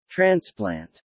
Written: transplant

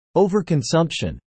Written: overconsumption

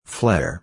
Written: flair

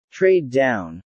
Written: trade down

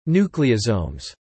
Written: nucleosomes

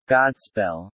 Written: Godspell

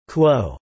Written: quo

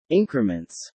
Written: increments

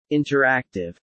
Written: interactive

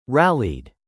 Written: rallied

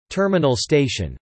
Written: Terminal station

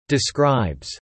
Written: describes

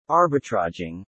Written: arbitraging

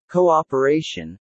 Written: cooperation

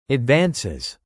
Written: advances

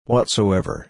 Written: whatsoever

